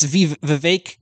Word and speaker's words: Vivek: I think we pretty much nailed it with our prediction that Vivek... It's Vivek rhymes with cake. We've been mispronouncing really? – It.